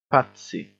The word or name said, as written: patsy